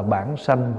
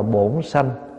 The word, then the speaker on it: bản sanh: Có khi mình đọc là bổn sanh, có khi mình đọc là bổn sự. Cách đọc của người miền Nam thì đọc là bổn sanh, bổn sự. Người miền Bắc đọc là bản sanh.